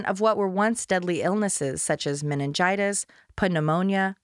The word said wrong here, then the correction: pneumonia: I'm sorry, that's pneumonia, the P is silent, and in the case of Blanche, Sophia alludes, chlamydia, gonorrhea, and syphilis, sexually transmitted infections.